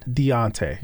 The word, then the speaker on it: Deuntay: D-E-U-N-T-A-Y. – And you pronounce it? – Deontay.